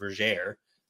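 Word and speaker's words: Vergere: And there's actually in the book that we were supposed to be talking about in Destiny's Way, Vergeer, it's actually pronounced Vergeer. Onimi makes a rhyme that rhymes air with Vergeer, and it's all actually rhyming. So it has to be Vergeer.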